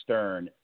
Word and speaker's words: Stern: It's Alec, Alec. Like Alec Baldwin or Smart Alec, Alec, and the last name is Stern, like Howard, Stern.